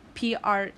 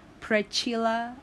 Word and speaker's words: Precylla: Priscilla, because the spelling is a little bit different. It's Precylla. So they're confused whether it's Prechila or Prekyla, you know. I don't know why it's so hard to pronounce, but yeah, it's a little bit unique in terms of spelling.